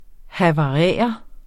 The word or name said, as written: havarere